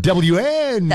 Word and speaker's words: WNBA: You have to stress the W. – WNBA.